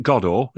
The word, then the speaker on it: Godo: Is it Godot? Is that how we're pronouncing it? – Waiting for Godot. Yes, indeed. Yeah.